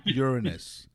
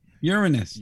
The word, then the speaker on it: Uranus: It's pronounced Uranus. – Uranus. – Uranus, yes.